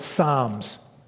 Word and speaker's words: Psalm: It's called Psalms. And yes, Psalms starts with a P, and don't ask me why to explain how that P is silent.